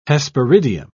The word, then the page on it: hesperidium: British English